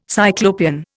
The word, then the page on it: Cyclopean: Indian